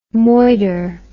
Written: moider

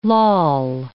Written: loll

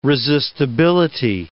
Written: resistibility

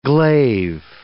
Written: glaive

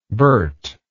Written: burt

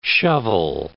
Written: shovel

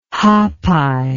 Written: hapai

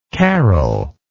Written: caryl

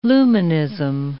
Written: luminism

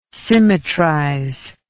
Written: symmetrize

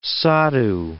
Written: sadhu

